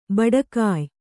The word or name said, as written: ಭಟ್ಟಗೆಯಿ